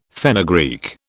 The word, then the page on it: fenugreek: British English